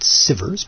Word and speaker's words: Sivers: I've got to quote him, so I'm going to go to YouTube and search for First Follower, and it's from Derek Sivers, S-I-V-E-R-S, so I'm assuming that's a long I, and it's not Sivers, but I'm assuming it's Derek Sivers.